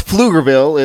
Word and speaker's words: Pflugerville: I've never heard of that, but well, it's Pflugerville. It's spelled with a silent P.